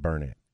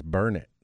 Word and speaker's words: Burnett: See, I said that wrong one time and they kind of got all over me. I said Burnett. And they're like, no, it's not Burnett. It's Burnett.